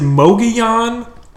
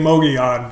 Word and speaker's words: Mogollon: You just don't pronounce the L's as L's. So it's Mogollon, right, or Mogollon. We were told both. It's not Mogollon. – Right. – It's Mogollon or Mogollon.